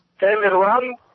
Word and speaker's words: Tamerlan: So this is what it sounds like in sort of their language, okay? – Tamerlan Tsarnaev.